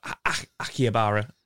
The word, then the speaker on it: Akihabara: And I, I, always butcher the name of the area that I stayed in, but Akihabara. I, I, that's the best I can do. I think you said it right there.